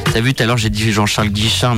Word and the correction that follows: Guichen: mais c'est notre invité Gwen de Kemp qui m'a dit ça se prononce Guichen.